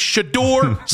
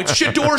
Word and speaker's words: Shedeur: Both people there mispronounced the name. Matt Corrali in his 2020 today mispronounced the name. It's not hard. It's Shedeur Sanders. It's Shedeur Sanders.